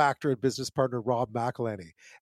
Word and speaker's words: McElhenney: It's McElhenney, McElhenney. What ways to massacre and mispronounce it, there are many. – McElhenney.